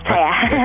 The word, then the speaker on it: Taya: Yeah, Taya.